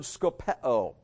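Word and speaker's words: skopeo (σκοπέω): The word mark them is the Greek word skopeo. Skopeo sounds like the word scope.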